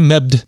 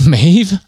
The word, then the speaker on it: Maeve: But like Queen, I always thought it's Queen Mebd because it's Mabd, but it's Queen Maeve apparently.